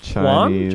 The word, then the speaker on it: yuan: They have yuan? – Chinese currency.